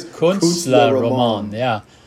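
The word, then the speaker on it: Künstlerroman: Kunstlerroman as well. Kunst, how do you say it? – Kunstlerroman, yeah.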